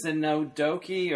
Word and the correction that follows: Synecdoche: or... But do you know what I'm talking about? – Synecdoche, New York. – Yes, Synecdoche, New York, right? Is that Synecdoche. – Synecdoche, New York. And I pronounced it wrong.